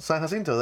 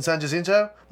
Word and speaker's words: San Jacinto: San Jacinto. That's the street down. Like, you mean San Jacinto? And I'm like, no, you're saying it wrong, motherfucker. That word's in Spanish.